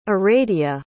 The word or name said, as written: Aradia